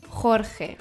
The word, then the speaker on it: Jorge: Das J ist stark betont und es kommt ebenfalls aus dem Hals. Und das G mit dem E oder I wird als Jota, wird als J ausgesprochen. Auch stark. Jota, Jorge. Ja, je, ji, jo, ju wird immer stark betont.